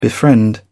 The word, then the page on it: befriend: British English